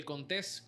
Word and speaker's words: Contés: Mi nombre es Miguel Contés, con acento en la E.